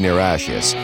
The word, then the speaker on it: Niratias: I know it sounds weird, but it's spelled N-I-R-A-T-I-A-S, Niratious.